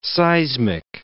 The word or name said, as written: seismic